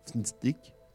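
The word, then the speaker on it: Fntastic: Ça se prononce Fnstick, c'est pas facile, Fantastique, avec le premier A au moins.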